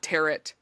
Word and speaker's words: tarot: There's actually, a bit of a debate, because, you know, tarot is spelled T-A-R-O-T. It's not tarot, anything like that. It's pronounced tarot or tarot, and I've heard both, and I think both are widely accepted.